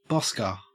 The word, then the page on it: bosker: Australian English